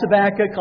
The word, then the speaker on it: Habakkuk: And you pronounce Habakkuk like you say tobacco. It's not Habakkuk. It's not Habakkuk. It's Habakkuk like tobacco.